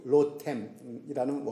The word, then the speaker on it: 로템: 로뎀은 히브리어 로템이라는 원래 히브리어 말입니다.